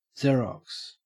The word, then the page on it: xerox: Australian English